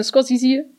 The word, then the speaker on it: Scorsese: Nein, das ist ein, ein offizieller Scorsese-Experte hat mir mal gesagt, der wird Scorsese ausgesprochen und ist wahrscheinlich der Einzige, der das tut. Hm. Naja. Äh, Scorsese, genau.